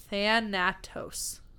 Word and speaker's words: Thanatos: Thanatos. Like Thanos, but add an extra syllable.